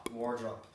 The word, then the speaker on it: Wardrop: Wardrop. You won't be the first to have said wardrobe.